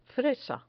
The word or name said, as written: fresa